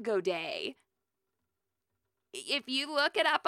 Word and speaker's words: Godet: I'm saying Godet. I'm just gonna. I looked it up. It's like I looked up, you know, G-O-D-E-T pronunciation, and in English, that's like how it's pronounced.